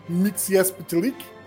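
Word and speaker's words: Mxyzptlk: Então, foi daí que eu tirei a minha pronúncia, que eu acho que é a canônica do... Mitch Spiklick, que é da série Lois e Clark.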